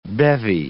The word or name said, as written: bevy